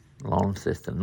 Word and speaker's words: Launceston: I'm getting told off the text it's Launceston, not Launceston. – Launceston. – How do you say it? – Launceston. – Launceston. – Launceston. Not long, lon, Launceston. – Yeah, L-O-N, not L-A-W-N, yeah.